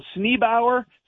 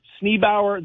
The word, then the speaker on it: Sneeboer: Sneebauer. So it's spelled S-N-E-E-B-O-E-R, Sneebauer, the Royal Dutch Hoe.